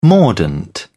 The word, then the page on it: mordant: British English